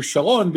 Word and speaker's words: Sharon: We're going to talk about it in a second, because I want you to go back to Sharon, because you said it correctly.